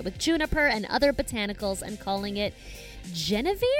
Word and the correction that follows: Genever: Well, I just looked that up and it's Genever, so I really Fucked up that pronunciation.